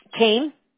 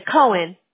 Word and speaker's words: Koehn: It's Kane, K-O-E-H-N. – Kane, that's how you pronounce it, right? – That's right. – K-O-E-H-N, correct? – That's right. – Okay. – Yep, you got it. – See, I would have said Cohen. – Yep. No, you can say it that way, too. – No, I want to pronounce it the way you said, Kane.